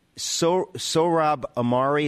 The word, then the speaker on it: Sohrab Ahmari: You said that Sohrab Ahmari, is that how we say his name? – Yes.